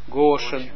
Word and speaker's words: Goshen: Hier zie je, staat het hier op de eerste, staat de klemtoon. Waarschijnlijk moet het hier uitgesproken als Goshen, klopt. Goshen.